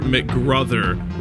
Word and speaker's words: McGruther: With me today on the other end is Jennifer McGruther, rhymes with brother.